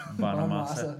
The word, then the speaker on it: Bonamassa: Um, I just totally pronounced his name. Bonamassa. Bonamassa. Yeah.